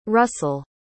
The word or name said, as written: russell